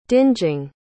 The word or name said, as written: dingeing